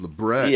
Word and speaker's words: labret: But I remember people used to come in for labret piercings all the time, and I'd be like, Actually, it's not a French word. It's pronounced Lebret.